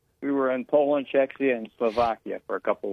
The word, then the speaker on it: Czechia: Czechia, yeah.